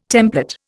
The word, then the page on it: templet: Indian